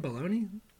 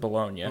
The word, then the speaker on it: Bologna: Does he pronounce his last name Baloney? Like, how does he do it? – I think he pronounces it Bologna. – Bologna? Mm. Like the... – And I think he's dead. – Okay. So then he can fuck off, and we're going to call him Bologna.